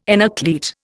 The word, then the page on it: enucleate: Indian